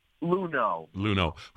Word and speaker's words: Luhnow: Is it Luhnow? Is that how we pronounce his name? – Luhnow,